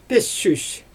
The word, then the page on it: pissüs: Xyzyl